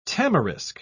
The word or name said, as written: tamarisk